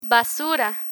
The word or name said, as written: basura